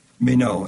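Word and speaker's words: meno (μένω): In other words, there were 121 occurrences in the New Testament of this Greek word, meno, M-E-N, long O.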